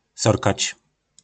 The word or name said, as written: Sorkac